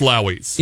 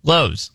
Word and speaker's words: Lowe's: I do believe it's pronounced Lowey's. – It is not. In fact, it is pronounced Lowe's.